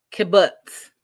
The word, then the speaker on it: kibbutz: Like, I've always heard of a kibbutz, but I don't even know if I'm saying it right. Kibbutz, that's how you say it.